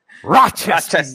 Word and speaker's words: Rochester: Okay, but say it with me now, Rochester. Just the CH, the hard syllables is crucial for me.